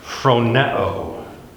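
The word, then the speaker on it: phroneo (φρονέω): He uses, get the right spelling here, phroneo. P-H-R-O-N-E long O. Phroneo.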